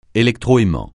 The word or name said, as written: électroaimant